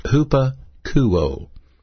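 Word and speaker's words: hupakouo (ὑπακούω): Now, this particular word, obey, is a Greek word pronounced hupakouo, H-U-P-A-K-O-U-O, hupakouo.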